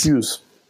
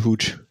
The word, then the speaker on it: Hughes: Wie spricht man den Namen eigentlich wirklich aus? Hughes. Hughes, okay. John Hughes.